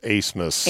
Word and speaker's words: Abmas: A-B-M-A-S, pronounced Asemus.